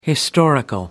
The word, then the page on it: historical: American English